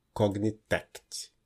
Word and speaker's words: Cognitect: que é onde fica a sede da Cognitect. Eu nunca sei pronunciar direito. Cognitech, Cognitect. Então acho que tem um Tzinho no final. – É, Cognitec.